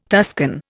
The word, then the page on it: Tuscan: Indian